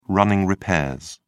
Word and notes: running repairs: British English

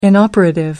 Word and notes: inoperative: American English

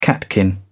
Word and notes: catkin: British English

